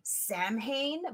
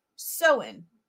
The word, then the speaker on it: Samhain: It was rumored that they were more powerful on the day of, it looks like it's pronounced, it's spelled Samhain, but it's actually pronounced Samhain. Really, really weird.